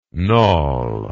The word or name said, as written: knawel